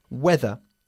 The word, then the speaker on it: whether: This is weather, spelt W-H-E-T-H-E-R. It's pronounced exactly the same as our word for el tiempo meteorológico, but it means the same as if.